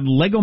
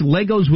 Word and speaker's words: Lego: But I learned that I'm not supposed to pronounce Legos with an S. WTF, the plural for Legos is our Lego.